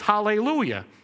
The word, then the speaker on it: hallelujah: And actually, in looking into that, praise the Lord, translated in Hebrew, it's hallelujah. And that's a three-syllable word. And so when we take that and we break it down, it's actually three syllables. Hallel, u, sha. And so hallel means shines, u means with, and jah, short for Yahweh, is God.